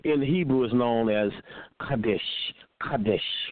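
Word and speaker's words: kadesh (קדש): That's Q-A with the long vowel sound up over the A, D-E-S-H, with the what? With the long, short vowel sound over the E. So it would be pronounced Kadesh, Kadesh, Kadesh.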